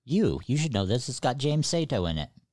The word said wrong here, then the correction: Saito: Oh, Saito. – Saito. There you go. That's how that's more pronunciable.